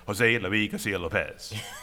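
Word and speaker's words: José Luis García-López: I should say, my teacher from the Cooper School brought him over, and his musical pronunciation of his name is like, I cannot literally pronounce his name other than Jose Luis Garcia Lopez.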